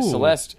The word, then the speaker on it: Celeste: Right. Celeste. It rhymes with molest.